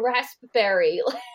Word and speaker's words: raspberry: It's like pronouncing the P in raspberry.